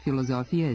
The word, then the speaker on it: filozofija: Međutim, u naš jezik filozofija je stigla preko latinskog i njegovog tradicionalnog zapadnog, to jest nemačkog stila izgovora, gde se s između samoglasnika izgovara kao z.